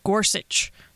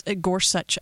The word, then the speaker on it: Gorsuch: You're listening to The Friddle Show, and we are going to start, of course, talking about Justice Gorsuch. I know it is such. The end of the name is such, but I don't know how you put them together. It's hard to say Gorsuch. I guess Gorsuch.